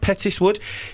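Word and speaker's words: Petts Wood: So, Mark in Pettis, is it Pettiswood? Have I pronounced that right? Pettiswood. – He said. You got a nod of yes from the producer.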